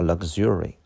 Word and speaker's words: luxury: 它有一个比较奇怪的发音叫 luxury。